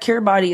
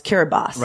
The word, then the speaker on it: Kiribati: Some users wrote us and said that Kiribati is supposed to be pronounced Kiribati. – Right. I think after we talked about it on the show, someone said Kiribati or Kiribati. – Yeah. So I double checked. You can say it both on Kiribati / Kiribati.